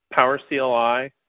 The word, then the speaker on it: PowerCLI: It's pronounced PowerCLI, not anything else you've heard in the community.